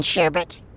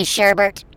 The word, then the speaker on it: sherbet: Did there used to be a second R in sherbet? – Didn't it used to be Sherbert? – No. – It never was? – We used to say it wrong.